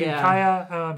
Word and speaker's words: Ukiah: Am I saying that right? – Yeah, Ukiah. Do you think? – Anna drives to Ukiah.